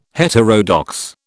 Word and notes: heterodox: British English

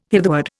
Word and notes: hitherward: Indian

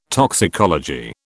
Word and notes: toxicology: British English

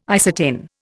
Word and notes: isatin: Indian

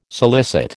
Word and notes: solicit: American English

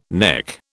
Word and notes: neck: British English

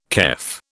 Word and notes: kef: British English

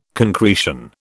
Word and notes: concretion: British English

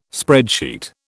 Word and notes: spreadsheet: British English

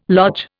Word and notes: lodge: Indian

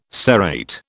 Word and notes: serrate: British English

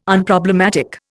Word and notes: Unproblematic: Indian

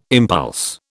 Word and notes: impulse: British English

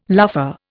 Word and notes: luffa: Indian

